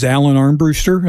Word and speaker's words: Alan Armbruster: My name is Alan Armbruster. That's spelled A-L-A-N-A-R-M-B-R-U-S-T-E-R.